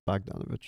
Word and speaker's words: Bogdanovich: How do you say it? Bogdanovich. – Bogdanovich.